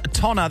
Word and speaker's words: Tonna: One of them is Tonna. That's with a T. Good morning, Tonna. – Good morning. – Tonna's an unusual name, Tonna. And surprisingly, Sean didn't mispronounce it, which is great.